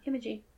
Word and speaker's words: Imogen: So also in this movie is Imogen Poots. – Imogen. – That's how you say her name? – Imogen.